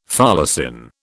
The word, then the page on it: folacin: British English